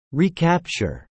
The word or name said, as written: recapture